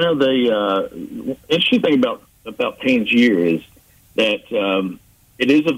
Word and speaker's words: Panjshir: The Panjshir province? – Panjshir province up in the north.